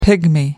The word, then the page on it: pygmy: American English